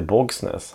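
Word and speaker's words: Bogsnes: Borgsnes. Hur uttalas? – Borgsnes. – Ja. Borgsnes. Ja.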